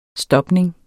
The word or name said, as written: stopning